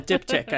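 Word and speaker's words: diptych: And we decided to go for I don't even know if you say that in English, diptyque. In Swedish we say diptyk. – Yeah, diptyk I think is the proper pronunciation.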